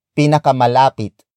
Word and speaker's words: pinakamalapit: Word for word, it's saan ang pinakamalapit na bar.